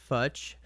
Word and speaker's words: Futch: Maui Realtor Dave Futch, rhymes with much, and Maloma Kula.